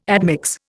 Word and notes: admix: Indian

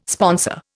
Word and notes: sponsor: Indian

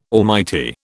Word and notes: almighty: British English

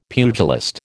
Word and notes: pugilist: American English

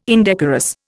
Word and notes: indecorous: Indian

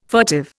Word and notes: furtive: Indian

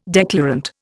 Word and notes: declarant: Indian

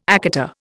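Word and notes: écarté: Indian

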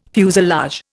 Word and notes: fuselage: Indian

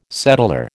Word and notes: settlor: American English

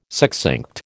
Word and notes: succinct: American English

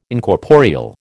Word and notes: Incorporeal: American English

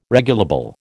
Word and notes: regulable: American English

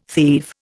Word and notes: thieve: Indian

